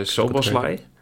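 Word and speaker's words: Szoboszlai: Maar het is uh, Soboslai.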